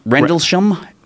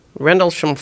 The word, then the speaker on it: Rendlesham: Rendlesham. – Rendlesham Forest. – Yeah, it was Bentwoods. It was two nights. – Sounds like a character actor on Columbo. – And it's a nuclear installation. – And Rendlesham Forest.